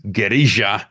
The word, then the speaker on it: guerrilla: Returning to the guerrilla, or as in Spanish is more accurately pronounced, guerrilla, information war battle space rebel transmission.